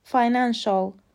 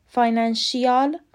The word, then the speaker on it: financial: F I N A N C I A L تلفظ درست فاینانشال فاینانشیال غلطه